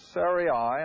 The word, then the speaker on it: Sarai: The name of Abram's wife was Sarai, and again I'm going to use the pronunciation as it's spelled.